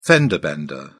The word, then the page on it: fender-bender: British English